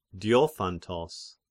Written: Diophantos

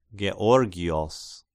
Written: Georgios

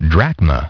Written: drachma

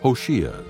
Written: Hoshea

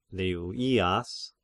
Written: Levias

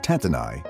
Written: Tattenai

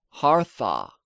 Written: Harthah

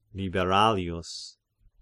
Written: Liberalius

